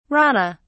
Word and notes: rana: British English

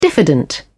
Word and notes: diffident: British English

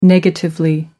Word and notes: negatively: British English

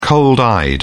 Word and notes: cold-eyed: British English

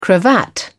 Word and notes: cravat: British English

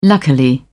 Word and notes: luckily: British English

